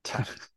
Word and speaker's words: Tybee: Tybee.